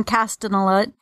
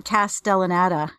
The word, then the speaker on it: Castellaneta: He's got this little purple assistant guy who's sassy but also rather smart and helpful, and he's voiced by uh, Dan Castanella.